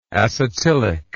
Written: acetylic

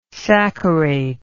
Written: saccharate

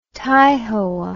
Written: taihoa

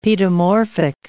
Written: paedomorphic

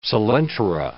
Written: coelentera

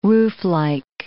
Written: rooflike

